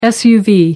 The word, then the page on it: suv: American English